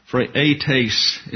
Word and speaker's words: Phraates: Phraates is the way it's pronounced in the Greek.